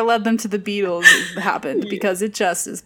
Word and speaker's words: Beatles: It's spelled B-E-A-T-A-L-S, but Beatles, like beat on a drum, is a tribute to Buddy Holly and the Crickets, which I didn't realize that.